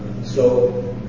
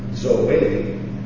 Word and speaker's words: Zoe: Zoe is how you pronounce it, and it means life. Your life is hid with Christ in God, Colossians 3:4. See how nice? When you see that transliterated, you would see it Z-O-E, and many would pronounce it Zo or Zozo, but it's wrong.